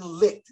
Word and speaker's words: Licht: General manager Jason Licht. Is that how you say it? – Yep.